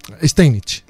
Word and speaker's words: Steinitz: de Zuckertot y con el gran maestro Wilhelm Steinitz. ¿Lo he dicho bien? – Steinitz.